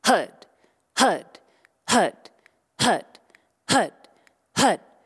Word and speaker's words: Har: The chant is spelled H-A-R, but it's pronounced HUD.